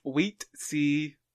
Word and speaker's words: Huitzilopochtli: So, according to Wikipedia, the god I chose is pronounced Huitzilopochtli? Huitzilopochtli, I think.